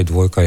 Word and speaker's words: Dvojka: A narobe izgovarjam naslov albuma, glede na dva velika ija, rimska dvojka, je kaj tretjega v ozadju? – Ne, dosi nam pravi idejo, da bi tudi mi to rekli dvojka, nismo pa vam do zdaj še zares imenovali.